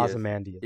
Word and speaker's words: Ozymandias: it's like a first name, but it's like a light on the Y, so it's an Ozymandias. – Ozymandias.